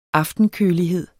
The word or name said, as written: aftenkølighed